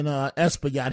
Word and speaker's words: Espaillat: And I think he miscalculated when he supported, you know, Espia. I always pronounce his name wrong. The congressman, the Dominican American. – Congressman Espaillat.